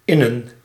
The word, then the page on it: innen: /ˈɪnə(n)/